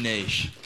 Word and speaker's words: niche: And by niche, I mean niche. – And by niche, you mean how it's meant to be pronounced.